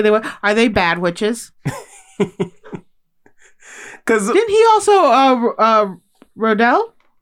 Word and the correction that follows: Roald Dahl: How do you say his name? – Roald Dahl. – It's Roald Dahl.